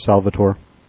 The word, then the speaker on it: Salvator: You know, it ends in A-T-O-R, but, you know, you try to pronounce it like Germans do, not what you would think an English pronunciation is, because that's wrong. So anyway, Polliner Salvator.